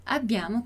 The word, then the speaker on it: abbiamo: Mas tem os dois Bs, então atenção, attenzione alla pronuncia. Então, o sonzinho demorado. Abbiamo, abbiamo.